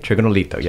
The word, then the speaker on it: Trigonolito: Now, did I say that right? – Trigonolito, yes.